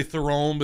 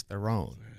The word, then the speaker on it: Theron: People like to say Theron, but it's freaking Theron.